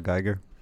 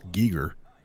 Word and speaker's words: Giger: Giger? – Yeah, accurate. Oh, yeah. – Fun fact. It's actually pronounced Giger.